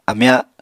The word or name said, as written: amya